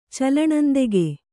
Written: ಚಲ್ಲಣಂದೆಗೆ

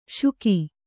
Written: ಶುಕಿ

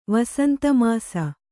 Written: ವಸಂತಮಾಸ

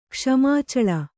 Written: ಕ್ಷ್ಮಾಚಳ

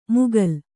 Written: ಮುಗಲ್

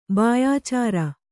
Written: ಬಾಯಾಚಾರ

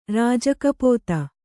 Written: ರಾಜಕಪೋತ

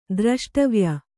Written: ದ್ರಷ್ಟವ್ಯ

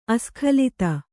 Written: ಅಸ್ಖಲಿತ

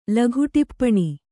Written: ಲಘುಟಿಪ್ಪಣಿ

